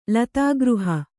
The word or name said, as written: ಲತಾಗೃಹ